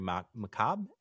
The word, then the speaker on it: macabre: Very macabre. Is that how you say it? – Macabre. – Yeah. – Yep. – Macabre. The R is kind of, fucky. – It's there. It's like Stark.